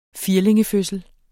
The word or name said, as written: firlingefødsel